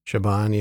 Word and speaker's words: Siobhan: And I don't know if I care about the daughter and her breakup, you know, Siobhan. – Yeah. – Did I say that right? – Siobhan, yeah.